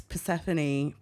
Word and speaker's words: Persephony: In case you're not aware, because this is obviously an audio format, it's Persephone, P-E-R-S-E-P-H-O-N-Y, which is not how you correctly spell it, but my government name ends in a Y.